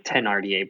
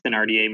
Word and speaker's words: Thénardier: Have you, I've always pronounced it with the H silent, like Thénardier, but I've heard it both. Have you heard it Thénardier more?